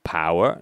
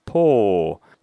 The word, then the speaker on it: pour: However, when you see the word pour, which means servir, servir agua o lo que sea, this is written as P-O-U-R. Exactly the same O-U as in flower, which is arena. But here the pronunciation is not flower, like power. No, it's paw. So P-O-U-R is pronounced as paw, just like door with two O's in it, yeah? D-O-O-R and P-O-U-R are both pronounced or.